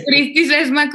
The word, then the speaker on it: Cristi Sesma: Pero como Cristi Sesma con I latina.